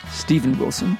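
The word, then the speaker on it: Steven Wilson: Are we into Steve Wilson? Stephen Wilson, excuse me, let me get his name right.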